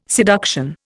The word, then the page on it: seduction: Indian